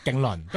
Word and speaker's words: 競輪: 發音或者叫做嗰個英文嘅拼音嘅寫法咧，都係 Killian 嘅，所以佢哋都會讀勁倫。